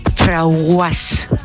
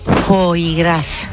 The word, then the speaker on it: foie gras: Pues yo tenía una clienta que cuando me iba a comprar cierta cosa decía, dame un frauguas. A ver si sabéis lo que es eso, porque yo el primer día no lo sabía. – ¿Eh? – Foie gras.